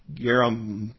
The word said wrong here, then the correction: Gemariah: no, that's not right. You guys had to look that up on your own here. Gemariah is the actually pronunciation there.